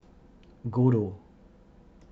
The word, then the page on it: Godot: godoh